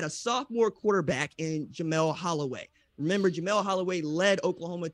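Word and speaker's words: Holieway: Holy Way? Am I saying that right? – Holloway.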